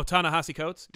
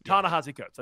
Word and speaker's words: Ta-Nehisi Coates: I can't ever pronounce his name, but oh, Ta-Nehisi Coates. – Ta-Nehisi Coates.